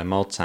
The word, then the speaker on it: molt: Per molts anys. – Si. – So I recognise a couple of words in there. It was molt. It was like very. – Mm-hmm. Mol, yeah, we don't pronounce the T.